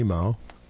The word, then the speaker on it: mow: Most rural barns had a hay mow. Mow rhymes with wow.